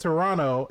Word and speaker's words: Toronto: In some scenes they said Toronto, and then in some scenes they said Toronto. – You never pronounce the second T, ever.